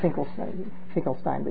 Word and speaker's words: Finkelstein: He spells it Finkelstein, but he pronounces it Finkelstein.